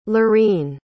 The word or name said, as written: laurene